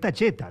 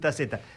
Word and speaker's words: Tacheta: Y, no, y además está bien pronunciado, porque es Tacheta. Lo que pasa es que estamos acostumbrados a decir Taceta.